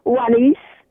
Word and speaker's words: Juanice: Juanice. – Juanice. – And it's spelled J-U-A-N-I-C-E.